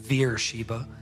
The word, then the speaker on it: Beersheba: So that word Beersheba, it's Hebrew. It's actually pronounced Beersheba. It's like a Bechika.